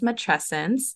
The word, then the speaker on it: matrescence: And so it's pronounced matrescence, and it kind of mirrors adolescence.